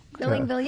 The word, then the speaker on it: Engvall: Bill Engvall, yeah.